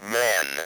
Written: man

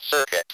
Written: circuit

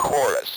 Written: chorus

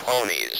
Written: ponies

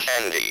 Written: candy

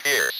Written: pierce

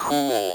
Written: cool

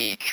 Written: each

